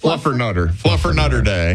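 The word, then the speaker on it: Fluffernutter: Happy National Flutter Mutter Day. – Excuse you? – Fluffer Mutter Day. – Nope. Try again. – Not for you? – You're still saying it wrong. – Oh, Fluffer Nutter Day.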